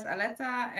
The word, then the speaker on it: Aletta: Hi, my name is Aletta and this is my second podcast interview.